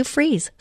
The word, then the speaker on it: Fries: Just go to Sue Freeze, spelled like fries, one word, .com, and connect with me there.